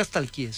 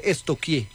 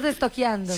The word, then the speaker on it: stalkear: Porque no se dice nunca stalkeé, se dice estoquié. – ¿Estoquié? Sí. – Pregúntele a la profe, verá. A mí una vez estamos estoqueando. – Sí, estoqueando. Yo, pero se escribe stalkear. Es estoquear.